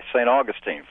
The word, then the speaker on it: St. Augustine: Hi, St. Augustine, Florida. – St. Augustine. You know what? I wasn't sure I was pronouncing that right. Thanks for letting me know.